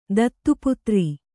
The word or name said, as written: ದತ್ತುಪುತ್ರಿ